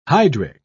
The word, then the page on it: hydric: British English